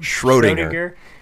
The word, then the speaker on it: Schrödinger: Schrödinger.